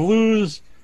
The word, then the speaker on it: Luz: And we're joined here with the director and the writer of Lose, The Flower of Evil.